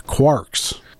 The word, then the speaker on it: Quarks: Or no. Quarks. – Yeah, pretty sure it's Quarks. – I still Quirks, mispronounced it. – Because Quirks is the with an I.